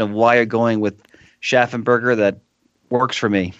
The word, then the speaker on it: Schaffenberger: often that particle Schaffen is anglicized to Schaefer or Schaffen in English. But since Mr. Schaffenberger is a first-generation German immigrant. He probably might have retained something closer to the original German pronunciation. So I'm going to go with Schaffenberger for the rest of this episode.